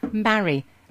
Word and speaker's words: Mary: There's no E on the end. And watch my lips. Mary. Rhymes with Harry.